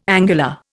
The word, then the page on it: angular: Indian